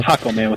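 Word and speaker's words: Jácome: Hakome, with an accent on Hakome. The first A.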